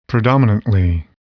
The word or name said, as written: predominantly